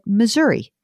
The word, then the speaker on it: Missouri: And then I've heard said Missouri.